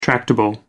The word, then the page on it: tractable: American English